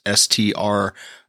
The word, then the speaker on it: strigil: I believe I'm pronouncing that correctly. It's strigil.